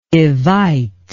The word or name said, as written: evite